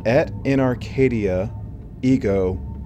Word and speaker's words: Et in Arcadia Ego: Et in Arcadia, Ego Part Two.